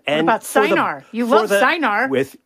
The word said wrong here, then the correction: Cynar: Chinar. Yes, it's actually Chinar. it's actually pronounced Chinar.